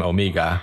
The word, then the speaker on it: Omega: But when this new character is introduced, she pronounces her name Omega. – Omega. – My name's Omega. – Which is really just it's a New Zealand accent of Omega.